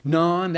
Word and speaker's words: naan: Naan, that's it.